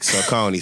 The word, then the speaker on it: Saucony: So write it phonetically. So like Saucony. So S-A-W-Coney.